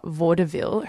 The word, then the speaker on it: vaudeville: So the book follows these women in the age of vaudeville. I hope I'm pronouncing that right. – Yes.